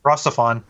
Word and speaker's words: RahXephon: It won a bunch of contests one year, and it was clips of Rafon? How'd you pronounce it? – Rossifon. – Rossifon, that was it.